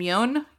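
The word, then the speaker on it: Yeun: I really want to see Beef with Ali Wong and Steven, I don't know how to say his last name, Yoon? – Yeah.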